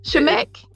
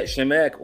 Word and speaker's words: Shamek: Shemek. Yeah, Shemek.